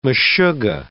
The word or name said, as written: meshuga